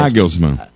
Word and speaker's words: Julian Nagelsmann: Nagelsmann.